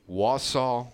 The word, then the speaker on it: Wausau: Make sure I say this right. Wausau Woodchucks, right? – Yep.